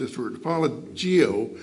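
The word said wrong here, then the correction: apologia (ἀπολογία): or apologia. Apologia is better pronounced. Apologia. And it probably sounds very much like apology.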